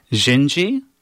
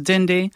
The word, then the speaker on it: Dindi: She's going to be singing Jinji, sometimes pronounced Dindi. It's Portuguese.